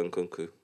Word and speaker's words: Nkunku: Christopher Nkunku.